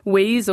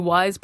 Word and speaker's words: Weyes: Right, okay, yeah, so it is spelled W-E-Y-E-S, so most people think it's Wheeze or Ways, but it's actually Wise Blood, like W-I-S-E.